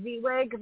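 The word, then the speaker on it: Vieweg: On Facebook, I am Emily Zweig. My last name is spelled V like Victor, IEWEG.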